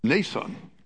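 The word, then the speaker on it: Mnason: Nason is a Greek word, a Greek name, and the M is silent. So it's pronounced Nason.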